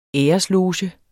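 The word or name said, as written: ÆRESLOGE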